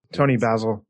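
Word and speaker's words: Toni Basil: Tony Basil.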